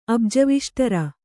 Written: ಅಬ್ಜವಿಷ್ಟರ

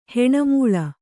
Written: ಹೆಣಮೂಳ

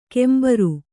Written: ಕೆಂಬರು

